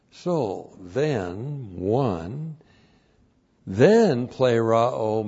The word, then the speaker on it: pleroo: Now, we have with us pleroo. Pleroo. P-L long E-R-O long O. Pleroo.